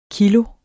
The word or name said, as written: kilo